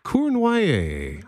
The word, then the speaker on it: Cournoyer: And today's story comes from Janice Cournoyer. I hope I said that correctly. I think you did based on my hockey knowledge, you said it absolutely perfect. Yvan Cournoyer, the legendary Montreal Canadiens player, mm.